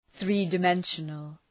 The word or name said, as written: three-dimensional